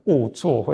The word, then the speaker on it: 恶作: 不好意思，刚才那个应该读成恶作会比较好了。